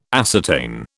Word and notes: ascertain: British English

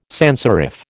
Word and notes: sanserif: American English

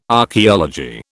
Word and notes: archeology: British English

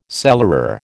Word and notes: cellarer: American English

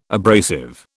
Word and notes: abrasive: British English

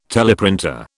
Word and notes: teleprinter: British English

